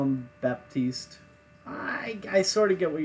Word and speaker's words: Baptiste: Baptiste. – Nope, not a Baptiste. Little. I'm gonna call him Baptiste. – Why can't you hear a Baptiste going to the bathroom? Because the pee is silent.